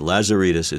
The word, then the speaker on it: Lazaridis: H-E-N-R-I-E-T-T-E. And Lazaridis is L-A-Z-A-R-I-D-I-S.